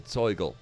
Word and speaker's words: Zoigl: And in the northeastern part of Bavaria, where I'm from, we have a beer culture called Zeugel. It's spelled Z-O-I-G-L.